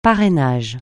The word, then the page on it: parrainage: francais